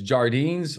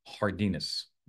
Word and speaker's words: Jardines: And Sean says Jardines, or is it Hardinas, right? I actually have been saying Jardines for many, many years because I don't speak Spanish. I don't know sometimes how to pronounce it. But I got to tell you, I've been corrected and I'm going to now do the right thing and say it correctly, Sean. It's Hardinas.